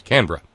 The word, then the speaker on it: Canberra: It may look like three syllables, but it ain't. The capital of Australia is Canberra. We pronounce Canberra as Canberra.